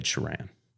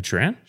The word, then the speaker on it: Sheeran: announced its 6900 Subtract by Ed Sheeran. – That's not how you say his name. – Ed Sheeran? – Sheeran. – Okay.